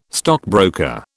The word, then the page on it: stockbroker: British English